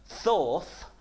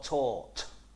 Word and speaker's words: Thoth: And it said the name of the god is pronounced either Thoth or Tort. Right, so we'll go with Thoth, okay.